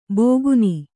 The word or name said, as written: ಬೋಗುನಿ